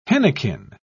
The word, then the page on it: henequen: British English